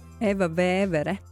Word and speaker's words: Eva Vevere: Eva Vevere.